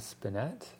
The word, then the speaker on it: spinet: His father bought him a spinet.